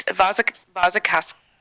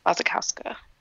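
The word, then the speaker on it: Wasikowska: It's Vazakowska. It's V's instead of W's how it's pronounced. – Okay, so there we go. – Is she German? – Vazakowska.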